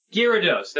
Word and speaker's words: Gyarados: I thought it was Gyarados. – Gyarados, that's right. My son keeps correcting me on this.